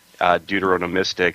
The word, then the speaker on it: deuteronomistic: which is very deuteronomistic. Did I say that right, Jared? – That was awesome.